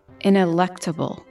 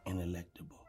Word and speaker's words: ineluctable: How do you say it? – Ineluctable. – There it is. The E. – Ineluctable. – Mm-hmm. Ineluctable. You were saying ineluctable. Like ineluctable. You were saying it with the N-E-L.